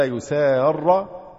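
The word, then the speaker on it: يسارّ: ولا يسار أحد يعني بالفتح، لأن هو الفعل إيه؟ هي أصلها ولا يسارر أحد، ها؟ ولا يسارر أحد الخصمين.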